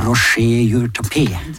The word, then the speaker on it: Roger Jourtapé: Men når det gjaldt Roy Jordtappen, vi også nevnte at han ville helst at navnet hans skulle uttales på fransk. Ja. Altså Roger Jourtapé.